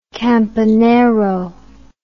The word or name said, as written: campanero